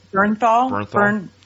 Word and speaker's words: Bernthal: The guy that plays the Punisher in the Netflix series, Joe Benthrall. – Oh, what's his name? I can't say his name properly. – Bernthal. – Bernthal.